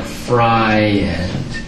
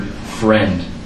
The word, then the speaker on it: friend: I would try to sound out the I, so it sounded like fry end. And she couldn't explain why you don't pronounce the I in this word while you do so in others.